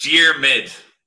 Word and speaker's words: Diarmuid: It's Diarmuid. Two syllables. Yeah. So the spelling doesn't help.